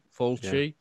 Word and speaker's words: Fulci: Fulci.